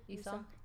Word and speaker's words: Esau: And it's when, I always say his name wrong, Esau, Jacob's brother, Esau.